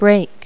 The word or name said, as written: brake